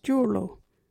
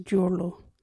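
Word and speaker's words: juurlu: Our local Wajiri word is Joorlo. What is Joorlo? Joorlo is salt.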